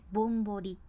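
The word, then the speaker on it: 雪洞: あこれはなんかさ何て言うかなよくひな祭りとかで聞くワードですねぼんぼり。漢字で書くと雪にほら穴のほら、うん、これは山水に同じって書くほらやけどこの雪にほらでぼんぼりって読むじゃん。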